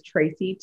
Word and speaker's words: Tracey: My name is Tracy Summerfork. You can find me on Facebook, LinkedIn, Instagram. And so that is Tracy, TRACEY.